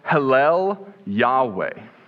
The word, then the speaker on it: Hallel Yahweh: In Hebrew, when you pronounce this through in Hebrew, it's pronounced Hallel Yahweh. And when you put that together, we actually get the word that we use in English, hallelujah.